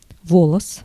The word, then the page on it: волос: /ˈvoləs/; volos